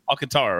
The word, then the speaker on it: Alcantara: I always pronounce his name wrong. Alcantara? It's Alcantara. That's right. Alcantara, right? Mm-hmm.